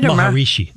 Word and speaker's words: Maharishi: And Prudence wound up spending all her time with the Maharishi. Is that how you say it? – Maharishi. – Maharishi Mahesh Yogi. – Thank you.